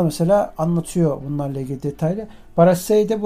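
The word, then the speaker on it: Brassaï: Telaffuzunu bilmiyorum nasıl okunuyor. Brassai diye okunuyor.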